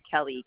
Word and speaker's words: Kelley: It's K-R-I-S-T-E-N. Kristen is my first name. My last name is Kelly, K-E-L-L-E-Y.